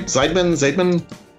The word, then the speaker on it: Zeidman: Zeidman? – Yes, Zeidman.